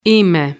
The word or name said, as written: είμαι